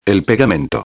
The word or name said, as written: el pegamento